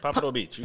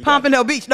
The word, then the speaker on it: Pompano Beach: Pompano Beach. – Pompano Beach.